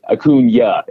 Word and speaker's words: Acuña: Acuña, it's got the little yeah at the end.